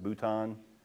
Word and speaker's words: Bhutan: Going into uh, Bhutan, that's how they pronounce it, Bhutan, um, which is a 100% Buddhist nation.